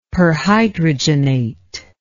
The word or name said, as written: perhydrogenate